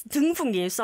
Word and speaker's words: 등풍기: You just said 동풍기. – Sorry. – Now, since you corrected my pronunciation, I'm going to correct you too. No. You said 동풍기. What is a 동풍기? – It's 등풍기. Sorry, guys.